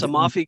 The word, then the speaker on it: Amalfi: You're down in the Amalfi Coast. Is that how you say it? – Yes, Amalfi Coast.